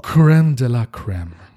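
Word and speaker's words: crème de la crème: Creme de la creme.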